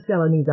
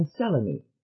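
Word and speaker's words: Selene: I am Selenie, all three syllables. Selenie," she said, coming down heavily on the first syllable, "to those who use my first name at all."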